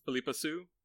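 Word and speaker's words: Phillipa Soo: Philippa Sue? – Yeah, or people just call her Pippa.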